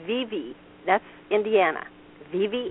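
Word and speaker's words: Vevay: Which is spelled Vive, but... I don't know why. When you go into town, you know, and say something about Vive, and the locals will say, it is Vivi.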